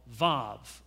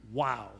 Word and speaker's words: Vav: There's a letter in Hebrew called Vav, or Wow, the V or the W.